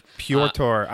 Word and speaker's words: Pyotr: Pyotr. I don't know how they're going to pronounce that Russian Peter, but... it's P-Y-O-T-R, so...